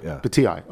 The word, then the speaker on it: Bhatia: Gets into a playoff with Bakhtai, right? That's how it's pronounced? – Akshay Bhatia, Bhatia, okay.